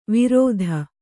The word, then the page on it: ವಿರೋಧ: kannada